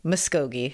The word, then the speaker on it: Muskogee: Muskogee.